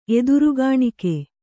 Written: ಎದುರುಗಾಣಿಕೆ

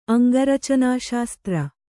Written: ಅಂಗರಚನಾಶಾಸ್ತ್ರ

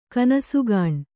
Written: ಕನಸುಗಾಣ್

